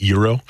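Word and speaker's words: gyroball: I believe it's pronounced Euro. – Euro.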